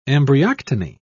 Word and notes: embryoctony: British English

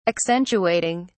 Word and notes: accentuating: British English

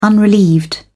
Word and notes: unrelieved: British English

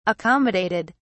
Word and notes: accommodated: British English

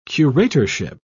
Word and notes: curatorship: British English

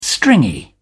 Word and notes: stringy: British English

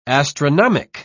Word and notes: astronomic: British English

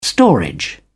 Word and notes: storage: British English

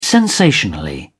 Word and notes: sensationally: British English

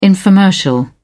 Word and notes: infomercial: British English

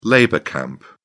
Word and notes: labour camp: British English

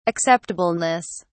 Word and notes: acceptableness: British English

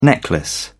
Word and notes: necklace: British English

